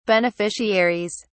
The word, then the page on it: beneficiaries: British English